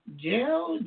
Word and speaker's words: Jael: Now we have, and I believe this name is pronounced Jael, J-A-E-L.